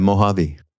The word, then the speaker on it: Mojave: Of course, he lived in, didn't he, the, I don't know, how do you say it, the Mojave Desert? – Yeah, Mojave. – Mojave, yes.